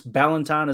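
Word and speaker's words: Ballentine: It is Ballantyne. Is that right? – I did hear that's what he pronounced it as, Ballantyne. So yeah, he said it right.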